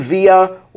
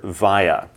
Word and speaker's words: via: Now, friends, this word via, you'll hear it pronounced a couple different ways. It could be via, or earlier you heard via.